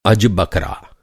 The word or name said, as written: अज